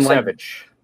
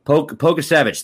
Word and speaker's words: Pokusevich: Who knows if the number 16 overall pick, I don't even know how to say his name, like Alexei Pokashev or something Pokusevich. – Pokusevich, thank you.